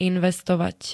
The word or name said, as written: investovať